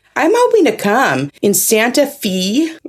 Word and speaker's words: Santa Fe: It looks like it's Santa Fe, but it's not.